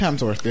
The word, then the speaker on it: Hemsworth: Now, I can never say his name. Helmsworth. Christopher Helmsworth, right? – Yes. – Thor. – Chris Hemsworth, yeah.